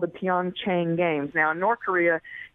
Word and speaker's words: Pyeongchang: And it's called, it's pronounced, is the P silent, Pyeongchang? – Pyeongchang.